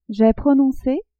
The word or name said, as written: j'ai prononce